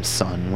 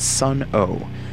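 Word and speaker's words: Sunn: This leads people to often mispronounce the band's name as Sun-O, but actually takes influence from the logo of the Sun brand of guitar amplifiers.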